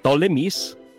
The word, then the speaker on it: Ptolemies: The Ptolemies sa Alexandria, may silent P doon sa spelling ng Ptolemies, at ang Seleucids sa Syria.